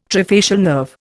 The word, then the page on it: trifacial nerve: Indian